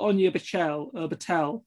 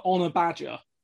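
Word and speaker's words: Ona Batlle: Again, it's sort of Anya Battel for Manchester United. Again, I've butchered the pronunciation there, so I'm going to sound very hypocritical. But they keep calling her Honor Badger on there. And we always, you know, we keep having a good laugh at it, because I know, you know, I know that my pronunciation isn't good, but I know that's nowhere near the correct pronunciation.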